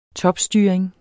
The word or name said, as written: topstyring